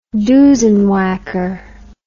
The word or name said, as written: doosenwhacker